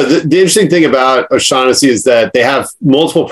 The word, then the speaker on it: O'Shaughnessy: And then a 2010, tell me if I'm saying this correctly, a 2010 Oshag Hennessy, Hell Mountain Cabernet Sauvignon for $95. – No, you know, it's funny. I was gonna mention the O'Shaughnessy wines because Oshag Hennessy.